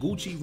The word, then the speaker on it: Gucci: I just, you know, perfectly and perfectly said, Gucci rhymes with booty.